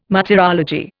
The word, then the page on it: martyrology: Indian